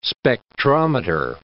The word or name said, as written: spectrometer